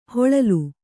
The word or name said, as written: ಹಿಳಿಲು